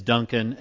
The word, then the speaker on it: Dunkin: Dunkin', and it is spelled like Dunkin' Donuts.